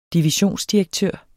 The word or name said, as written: divisionsdirektør